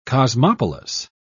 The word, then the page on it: cosmopolis: British English